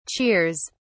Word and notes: cheers!: British English

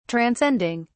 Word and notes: transcending: British English

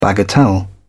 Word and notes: bagatelle: British English

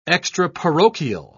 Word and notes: extraparochial: British English

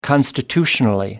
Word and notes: constitutionally: American English

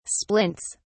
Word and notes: splints: British English

